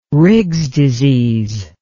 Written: Riggs disease

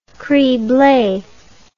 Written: crible

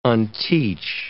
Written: unteach

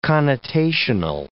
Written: connotational

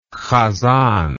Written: hazzan

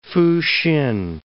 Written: Fusin

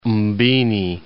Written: Mbini

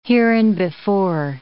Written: hereinbefore

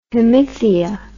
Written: hemithea

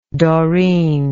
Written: doreen

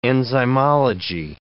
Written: enzymology

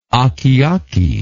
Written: akeake

